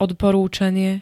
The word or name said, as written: odporúčanie